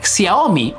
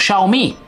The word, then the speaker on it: Xiaomi: En la tecnología, por ejemplo, Xiaomi o Xiaomi, ¿ok? Con eso, como se pronuncia en tu país.